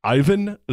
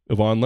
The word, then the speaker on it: Ivan: One as a tennis video game on a Nintendo, the old school regular Nintendo, used to pronounce his name Ivan Lendl. But Yvonne Lendl,